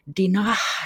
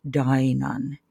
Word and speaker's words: Dinah: Koska niin kuin todellakin 8V-lukija kyllä nyt ymmärtää, että tämä Dinahn tarkoittaa, että se H ei äänny ja omistusmuoto lausutaan Dainan koska 8V suomalainen lukija ääntää Dinahin päässään Daina eikä Dina.